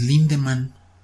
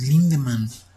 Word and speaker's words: Lindemann: Y hace tres años me di cuenta que no, es Lindemann. y yo, pero ¿de dónde vergas aquel la R? Digo que años escribiéndolo, viéndolo, leyéndolo, pronunciándolo, y ahora no, es Lindemann.